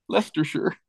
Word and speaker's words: Leicestershire: Leicestershire. Oh. How do you pronounce the stuff that you put on steaks? – Worcestershire. – Yes, exactly. Worcestershire. Not Worcestershire, right? So it's Leicestershire.